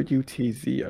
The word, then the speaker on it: Utz: Also, is it Utz or UTZ? I've been worried. – I've always just said Utz. – Yeah, like I'm sorry. UTZ is too many syllables.